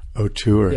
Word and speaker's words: auteur: Like A-U-T? How do you pronounce it? – E-U-R. – Auteur.